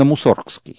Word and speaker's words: Мусоргский: Дело в том, что мы, ну, я буду говорить для привычки, как бы, продолжать Мусоргский, но вообще я хочу, чтобы слушатели знали, вообще-то правильно Мусоргский.